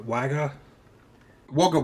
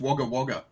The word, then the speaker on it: Wagga Wagga: So is it pronounced Wagga Wagga? – Wagga Wagga. It's pronounced Wagga Wagga.